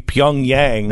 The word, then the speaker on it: Pyongyang: Pyongyang. – Pyongyang. – Pyongyang. – Pyongyang. – Pyongyang.